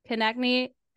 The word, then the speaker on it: Konecny: I don't know how to say his name. – Konecny. – Travis Konecny, ex-Nolan Patrick.